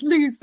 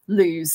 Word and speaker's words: Lewes: i've never really quite got why people pronounce it loose so it's just really funny um but yeah it does come up all the time and like within like our core fan base there is a bit of a joke as in like you know if you're not a real fan then you pronounce and you're you know you're from overseas and you're you know not not perhaps coming to the club very often that you'd be pronouncing it lose.